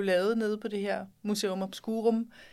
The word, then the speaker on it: Museum Obscurum: Museum Obscurum.